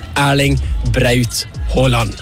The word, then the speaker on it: Erling Braut Haaland: It's Erling Braut Haaland.